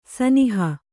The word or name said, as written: ಸ್ನೇಹ